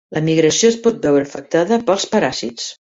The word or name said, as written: afectada